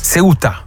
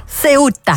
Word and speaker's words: Ceuta: Ceuta. – Ceuta.